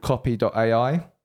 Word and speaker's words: copy.ai: This is a really cool tool called copy.ai. – Mm-hmm. – And copy AI. – Yeah, that's it. – That's how I pronounce it.